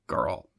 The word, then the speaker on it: Geralt: It's the hard G. – Geralt.